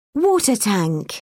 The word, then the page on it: water tank: British English